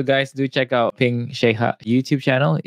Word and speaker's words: Sheikha: Oh, Sheikha. Okay. Sorry, yeah, I pronounced that wrong.